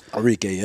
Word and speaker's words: Arike: Arike, is that pronounced? Am I pronouncing that right? – Arike, yep.